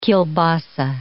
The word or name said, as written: kielbasa